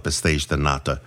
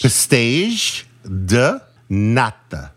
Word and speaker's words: pastéis de nata: And of course, we cannot miss, of course, you must go to the Confeitaria de Belém, which is where they sell the pastéis de nata, fresh out of the oven, amazing, totally amazing.